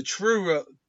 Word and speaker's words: Truro: It sounds like churro murders when I say it. The Truro on May 23, 1979, James Miller was brought in for questioning based on a tip from a former girlfriend.